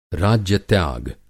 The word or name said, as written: राज्य-त्याग